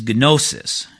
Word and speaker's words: gnosis: and in Greek the word for knowledge is gnosis. At least that's how they think they pronounce it in Greek, G-N-O-S-I-S. But when we anglicize it, we don't say gnostics, we say Gnostics.